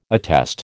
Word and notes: attest: American English

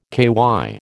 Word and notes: KY: American English

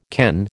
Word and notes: ken: American English

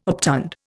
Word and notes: obtund: Indian

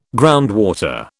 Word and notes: groundwater: British English

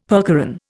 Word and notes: percheron: Indian

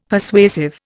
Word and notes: Persuasive: Indian

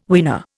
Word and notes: weaner: Indian

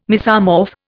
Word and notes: mesomorph: Indian

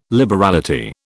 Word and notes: liberality: British English

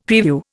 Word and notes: preview: Indian

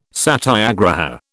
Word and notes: satyagraha: British English

